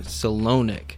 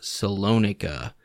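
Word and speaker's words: Solonik: Today we're going to be talking about Alexander Salonik, sometimes pronounced Salonika, who was a Russian gangster known for his reputation as a notorious hitman in the Russian criminal underworld.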